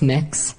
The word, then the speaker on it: Mx.: Not Mr., not Mrs., MX. Mix. And he doesn't want to say it because it's frickin' weird. – Tell me what you're proposing. – It's pronounced mix.